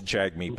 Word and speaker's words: Jagmeet: And just to it's Jagmeet, by the way, Singh is the proper pronunciation of his name.